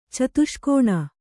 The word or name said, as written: ಚತುಷ್ಕೋಣ